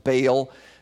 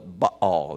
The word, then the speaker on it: Baal: You can hear him pronounced Baal. That's legitimate, although the Hebrews would have called it Baal. They didn't have a long A.